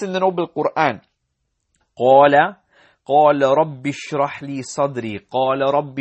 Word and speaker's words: رب: Technically, it's like قال يا ربي, يا ربي, يا ربي, but we do not pronounce the ya, nor the ya at the end.